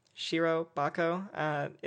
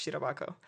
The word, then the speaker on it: Shirobako: Shirobako is the West Texan way of pronouncing that, but it's Shirobako.